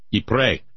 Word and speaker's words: Ypres: Here's this thing, Jim would talk about the third campaign in Belgium, what's that YPRES name, which I can never pronounce? – Ypres. – Yeah, thank you.